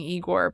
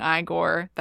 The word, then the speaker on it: Igor: But the name pronunciation, not being Igor, being Igor, that plays so well off of Frankenstein too.